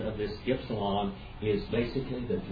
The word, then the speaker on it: ypsilon: And by the way, this is the way you pronounce this, ypsilon. See that word, delta, ypsilon, nu? It's not in Black Davis's Great Grammar, many of the grammars, it will pronounce it oo, ypsilon. But it's not oo, it is it, like a y.